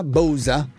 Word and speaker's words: Bausa: Oppure come si pronuncia? Bausa? – Sì.